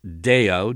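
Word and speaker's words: deo (δέω): And this verb here in Matthew 12:29 binds, it's deo, D-E long O in Greek.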